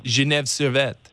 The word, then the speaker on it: Genève Servette: How was Ben's pronunciation of your team name? – Genève Servette. It's French. – Perfect. I wouldn't have said it that way.